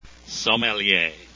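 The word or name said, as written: sommelier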